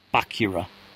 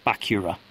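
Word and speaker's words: Bacurau: That is B-A-C-U-R-A... You. And it's Bakura. Bakura.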